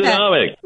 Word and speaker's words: Newsonomics: News on how do I say Newsonomics. – That? Newsonomics. Yes, Newsonomics.